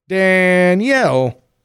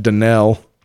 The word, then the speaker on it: Danielle: Danielle. Some folks in Michigan might pronounce that Danelle. – But really? – They'd be wrong. – Do they really? – Really fucking wrong.